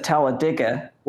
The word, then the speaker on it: Talladega: No. They pronounce it Talladega.